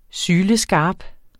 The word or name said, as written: syleskarp